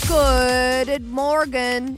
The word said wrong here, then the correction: Guten Morgen: Is that how you say it? Guten Morgen. – Guten Morgen. – Guten Morgen. That's German for good morning.